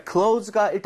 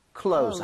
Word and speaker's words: clothes: 그래서 Z로 발음하셔도 문제가 없습니다. – 아. – So clothes. Close. 해도 소통하는데 전혀 문제가 없으니까요.